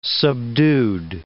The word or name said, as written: subdued